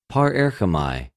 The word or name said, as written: παρερχομαι